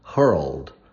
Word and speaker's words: hurled: But here we have, attention to those of you learning English as a second language, the pronunciation, like the verb, he was hurled to the earth. It's like world, hurled, girl. So you have to roll your tongue, try to do that.